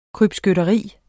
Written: krybskytteri

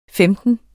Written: femten

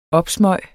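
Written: Opsmøg

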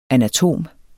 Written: anatom

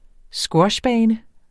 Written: squashbane

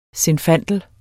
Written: zinfandel

